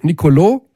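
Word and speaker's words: Nicolò: Nicolo, schöne Betonung auf der letzten Silbe gerade von dir, Rolf übrigens, spielt immer. Spielt immer. – Der Akzent auf der letzten, auf dem O, genau, deswegen ist er lang.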